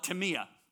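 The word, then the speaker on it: timia (τιμή): The Greek word for honor here is the Greek word timia. Now, you would pronounce it time, but the Greeks call it timia.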